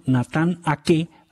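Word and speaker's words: Aké: Y Nathan Ake, así dicen que se pronuncia, en una aplicación se llama Forbo.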